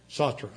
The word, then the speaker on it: Sartre: Wait, how do you say it? – Sartre. – Okay, I always say it wrong.